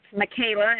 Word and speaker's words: Makayla: But it's Michaela, and I'll spell it because people tend to not really want to be able to spell it right.